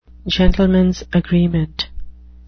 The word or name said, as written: gentleman's agreement